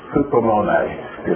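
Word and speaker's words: Hupomone (ὑπομονή): I have no idea if it has an accent, but I do know you're supposed to pronounce the last syllable. Hupomone is what it's called.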